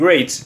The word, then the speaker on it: great: Watch out for meat and great and threat. They rhyme with sweet and straight and death.